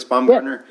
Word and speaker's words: Baumgartner: Did I say his name right? Felix Baumgartner? – Yeah.